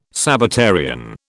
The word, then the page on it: Sabbatarian: British English